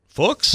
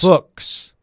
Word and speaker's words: Fuchs: Titans top offensive line assistant Scott Fuchs was at, it's actually rhymes with books. – Fuchs? – Fuchs.